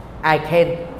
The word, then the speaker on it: ICANN: Thì đó, ví dụ như có một cái tổ chức tên là ICANN, viết tắt trong tiếng Anh là Y ngắn C-A-N, ICANN, phát âm á, là tôi có thể.